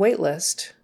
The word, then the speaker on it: waitlist: Um, we have an open waitlist for this. Open waitlist. Open waitlist. Um, you probably would pronounce the T on the end. Open waitlist. We have an open waitlist for this. You wouldn't drop it.